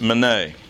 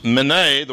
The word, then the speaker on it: mene: Daniel pronounces the first word, mene. Pronounced mene, the word is a noun that means mina.